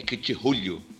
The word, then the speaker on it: Cthulhu: Na realidade, o nome é impronunciável, né? Então, se quer C-T-H-U-L-H-U é difícil. É Cthulhu, Cthulhu. É bem estranho.